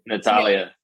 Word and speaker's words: Natalia: Do you agree with how Captain Sandy was towards, is her name Natalie or Natalia? Because now I feel like I'm saying it wrong. – Natalia.